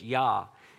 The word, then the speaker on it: Jah: you hear in that last part, we spell it in English, J-A-H, Yah.